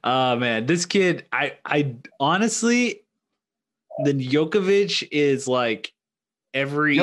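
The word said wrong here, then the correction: Jokić: Jokovic, you're adding syllables. Jokovic. Adding Jokic.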